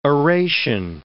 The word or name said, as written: oration